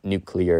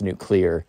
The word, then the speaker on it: nuclear: Sorry, I pronounced it wrong. Not nuclear, it's nuclear. My grandmother loves telling me, like, nope, wrong, because I'm always like, nuclear, she's like, nuclear, which I might as well pronounce it correctly.